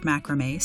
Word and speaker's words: macrame: Note that both the French and Italian counterparts of macrame stress the final syllable, while English stresses the first.